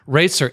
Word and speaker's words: Roetzer: Roetzer, as if it's R-A-T-E-Z-E-R. Okay.